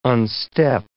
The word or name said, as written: unstep